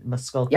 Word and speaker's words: Muskogee: Muskogee.